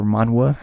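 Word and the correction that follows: Manwë: Manwe, yeah.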